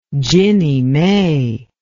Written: ginnie mae

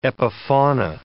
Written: epifauna

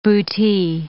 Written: bootie

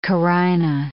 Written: carina

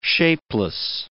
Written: shapeless

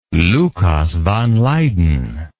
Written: Lucas van Leyden

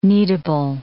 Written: kneadable

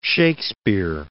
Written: Shakespeare

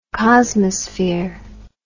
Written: cosmosphere

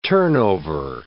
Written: turnover

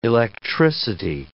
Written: electricity

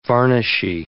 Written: varnishy

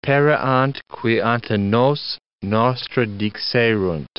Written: pereant qui ante nos nostra dixerunt

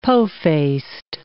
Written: po-faced

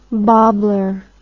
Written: bobbler

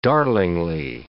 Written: darlingly

